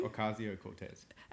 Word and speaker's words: Ocasio-Cortez: Ocasio-Cortez. – Alexandria Ocasio-Cortez.